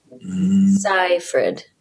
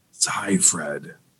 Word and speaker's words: Seyfried: If you're looking at it, you take the E's out. Seyfried. – Seyfried.